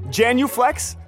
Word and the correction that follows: genuflect: Genuflect is when you bow before you get into a pew at church. – Oh my God, genuflect? Am I saying it right?